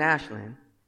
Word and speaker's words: Aslan: But there's a scene when the lion Aslan, what? I knew I was going to get his name wrong. What is it? Aslan. That was just my accent. I said it right.